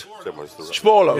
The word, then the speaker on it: Spoorloos: Spoorloos, yeah.